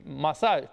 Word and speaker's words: masaj: Zice, unisex intimate message, masaj, nu message, e masaj, cum să pronunț, bă, masaje, ăsta, cum să pronunce masaje.